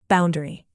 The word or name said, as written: boundary